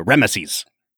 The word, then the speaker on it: Ramesses: It's not Ramses, oh, yeah. it's a Ramesses. Ramesses.